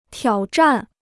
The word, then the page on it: 挑战: tiǎo zhàn